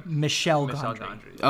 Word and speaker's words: Michel Gondry: Michel Gondry.